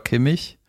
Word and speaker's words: Kimmich: Ist irgendwie ein großes Thema gerade, und zwar Joshua Kimmich. Mhm. Gut, dass die CH-Aussprache gerade richtig gemacht hat mit Joshua und dann Kimmich.